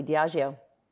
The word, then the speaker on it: Diageo: Diageo? – You are. – Perfect. – Are. – Cool. – You pronounce all right. – It perfectly. Diageo.